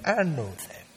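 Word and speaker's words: Anothem (ἄνωθεν): Anothem. A lovely word. Long O. Anothem.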